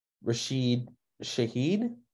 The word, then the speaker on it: Rashid Shaheed: Yeah, so I think the first rookie we want to talk about, and I'm probably butchering his name, but Rashid Shaheed.